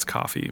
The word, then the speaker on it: Ko-fi: We'll get like a Ko-fi account or whatever. – Ko-fi? – K-O-F-I. I think it's coffee, but it's spelled K-O-F-I.